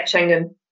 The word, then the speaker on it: Schengen: Schengen countries. I'm not sure if I'm pronouncing that correctly. – That's absolutely correct. Yeah, Schengen.